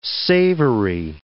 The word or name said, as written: savoury